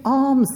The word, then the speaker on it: alms: Give alms, giving alms. I just wanted to define that and make sure we knew what that was. Alms rhymes with palms.